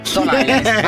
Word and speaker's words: Stone Island: Di Jim, che c'è la questi jeans della Stone Islands. – Stone Island. – Ma perché scrivono? Scrivono come si legge. – Si chiama così, Stone Island.